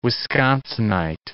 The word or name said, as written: Wisconsinite